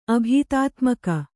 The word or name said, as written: ಅಭೀತಾತ್ಮಕ